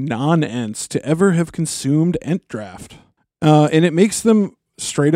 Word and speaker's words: Entdraught: Yeah, this is the Entdraught, or as I guess we would say it here, Entdraft. – Draft, yes.